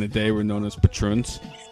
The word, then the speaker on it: Patroons: And then that's how you say it, the Patroons? – The Patroons, yeah. – Wow. Yeah. I thought it was the Patrons.